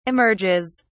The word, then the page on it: emerges: British English